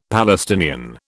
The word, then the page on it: Palestinian: British English